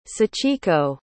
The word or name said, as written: sachiko